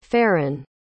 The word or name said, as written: farin